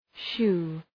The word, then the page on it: shoe: ʃu: